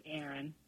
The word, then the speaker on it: Erin: Oh, my name's Aaron. – Aaron. – Spell that. – E-R-I-N.